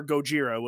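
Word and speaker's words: Gojira (ゴジラ): or Gojira, which would be the Japanese pronunciation of it.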